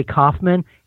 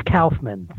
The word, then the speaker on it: Kaufman: Most people say Kaufman. It's Kaufman.